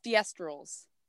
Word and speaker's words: thestrals: It's what are thestrals? – Oh, basically said the same thing. I just pronounced it wrong.